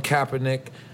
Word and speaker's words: Kaepernick: So Colin Kaepernick, did I say it right? – Yeah.